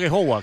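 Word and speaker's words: Aghehowa: He dicho a Gehowa, ¿cómo se pronuncia? – Sí, bien, bien, la verdad que... Bien, sí, sí, así, muy bien, muy bien.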